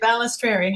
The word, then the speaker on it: Balestrieri: Did I say it right? You did, Balestrieri.